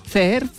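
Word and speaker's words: Ceer: Bueno, pues el príncipe de Saudí ha lanzado hoy los primeros automóviles de la compañía CER. O CER, porque se escribe con C, 2E y R. – Ya he visto uno aquí. – Se llama, ¿sí? Se llama Esobot. – CER, para creer.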